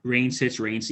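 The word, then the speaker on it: Wraneschitz: Reinschitz.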